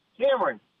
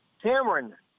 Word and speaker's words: Cameron: Cameron. – What is it? – Cameron.